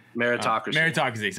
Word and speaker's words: meritocracy: I want to say metrocracy, but I can't say it right anyway. – Meritocracy. – Sorry, I was like, I'm missing a syllable.